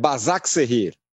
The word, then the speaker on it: Başakşehir: Eu sou incapaz de falar o nome do time de Istambul, Başakşehir. – Eu falei errado na abertura. Eu falei errado na abertura, é Başakşehir.